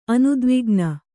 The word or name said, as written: ಅನುದ್ವಿಗ್ನ